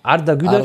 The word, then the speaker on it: Arda Güler: Fuck, ich will ihn nicht falsch aussprechen. Güler. Heißt er Arda Güler? Arda Güler, ja.